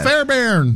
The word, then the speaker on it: Fairbairn: It's Fairbairn.